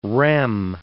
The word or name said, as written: rem